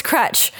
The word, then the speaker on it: Kroetch: I didn't know I had to be the one to pronounce my German last name. – What is it again? – Kroetch? Oh, it's Kroetch.